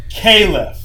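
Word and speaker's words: Keyleth: Okay, well, it's spelled K-E-Y-L-E-T-H, K-E-Y. – L-E-T-H, Kalef.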